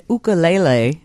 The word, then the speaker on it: ukulele: All right, I see you have an ukulele, is how they say it in Hawaii. Would I be correct in saying that? – Oh, yeah, Hawaii.